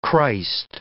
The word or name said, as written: Christ